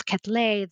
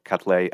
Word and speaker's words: Quetelet: Q-U-E-T-E-L-E-T, and Quetelet. I hope I'm pronouncing that correctly.